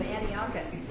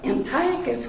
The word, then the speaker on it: Antiochus: The C-H is always a K. So when you look at Antiochus, I don't want to say it wrong, you have a tendency to put a like an English pronunciation on that with a C-H.